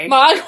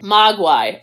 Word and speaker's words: Mogwai: Mogwai. But yes. – Okay, what I hear and what I spell never match up. Wait, how do you say it again? – Mogwai.